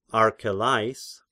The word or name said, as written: Archelais